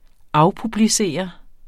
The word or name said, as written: afpublicere